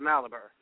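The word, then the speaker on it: Mallaber: Of course, this album has quite a history because you have on drums what's the pronunciation of his last name, Gary? Is it Malibur? – That's correct. – Malibur, yeah.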